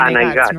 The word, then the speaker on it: Anaigats: Clar, el títol és Anaigats o Anaiguats? – Anaigats, no? Anaigats, sí, perquè a l'Empordà en diem aiga, pronunciem d'aquesta manera, i en Xavier Cortadellas ha mantingut l'essència de la paraula Anaigats.